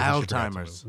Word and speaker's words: Alzheimer's: She's got old-timers. – Alzheimer's. – No, that's how you say it.